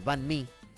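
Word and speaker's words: bánh mì: Tienen uno de los mejores sándwiches del mundo, el banh mi. – ¿El? – Banh mi.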